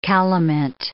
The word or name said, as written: calamint